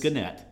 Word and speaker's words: Gannett: Pronunciation is Gannett. – Gannett. – Not Gannett, or what? I've had so many variations. – Listeners, you have now been informed on how to pronounce it correctly.